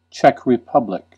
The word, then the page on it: Czech Republic: American English; [ˌtʃɛk ɹɪ.ˈpʌb.lɪk]